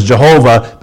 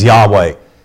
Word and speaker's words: Yahweh: Used to be more pronounced as Jehovah, but usually today it would be pronounced as Yahweh.